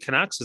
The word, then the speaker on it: Canucks: Is it Canucks? Is that how you say it? Yeah.